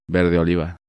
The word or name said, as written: Verde oliva